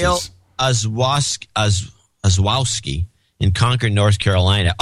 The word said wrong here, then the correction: Yael: And it's pronounced Yael.